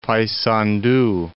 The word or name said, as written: Paysandu